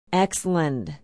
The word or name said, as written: exeland